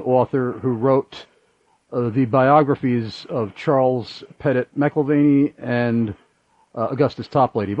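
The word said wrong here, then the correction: McIlvaine: By the way, is it McIlvaney or McIlvain? – McIlvain, I believe. – Okay, well, I've been mispronouncing it then.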